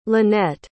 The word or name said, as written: lynnette